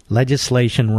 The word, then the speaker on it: legislation: Donnie, legislation rhymes with legislature, right? Legislature. Who are the legislators?